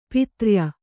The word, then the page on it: ಪಿತ್ರ್ಯ: pitrya